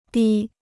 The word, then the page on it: 低: dī